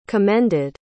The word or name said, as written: commended